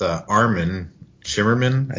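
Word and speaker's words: Armin Shimerman: So I read an interview with Armin Shimmerman, I think that's how you say his name. – Yep.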